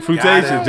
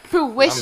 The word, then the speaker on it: fruition: No, fruitation. Just like toxtivity. – Fruitation. Oh, shit. Fruitation. He said fruit what? I was like fruitation. Fruition.